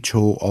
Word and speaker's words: 조: completely messing that one up now. – 초. – 초. Ah, we're talking about different syllables. – Yes. – 초. Whereas 조. – Yes. – This is 조, the softer one.